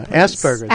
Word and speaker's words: Asperger's: Asperger's. – That's how you pronounce it? Asperger's. – Oh, really? Uh-huh. Oh, okay. – That's right. – I stand corrected.